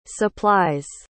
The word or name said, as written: supplies